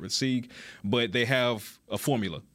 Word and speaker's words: Sieg: Potentially bringing in Trent Sieg. Am I pronouncing that right? – S-I-E-G? Yeah, I believe so, yeah.